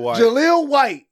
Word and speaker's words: Jaleel White: Jaleel White. How do you say his name? – Jaleel White.